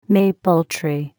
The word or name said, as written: maple tree